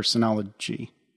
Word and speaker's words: Synology: A little birdie told me we maybe should talk about Synologies or Synology. – Is that how it's? Yeah, it's because it's pronounced like synergy, but with ology at the end.